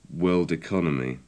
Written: world economy

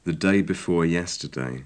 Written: the day before yesterday

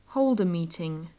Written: hold a meeting

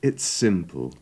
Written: its simple

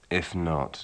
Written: if not

